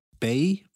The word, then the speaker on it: Bae: Und zweiter Platz ist Bay, wird das, glaube ich, ausgesprochen. Also B-A-E.